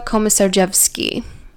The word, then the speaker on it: Komisarjevsky: So the two men were 44-year-old Stephen Hayes and 26-year-old Joshua Komisarjevsky. – Wow, nice pronunciation.